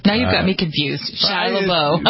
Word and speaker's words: Shia LaBeouf: Shia LaBeouf. – Shia LaBeouf. – Shia LaBeouf. Even though it's spelled LaBeouf.